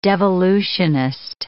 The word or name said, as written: devolutionist